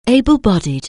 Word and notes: able-bodied: anglais

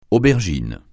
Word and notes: aubergine: francais

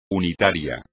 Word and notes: unitario: espagnol

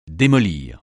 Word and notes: démolir: français

